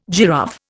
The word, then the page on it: giraffe: Indian